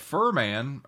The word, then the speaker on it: Furman: If you had Furman, or I believe it's Furman, is how it's pronounced.